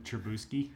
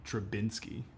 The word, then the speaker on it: Trubisky: I like that a lot of people have been calling him Trubinsky. – Trubinsky? – Just completely butchering his name.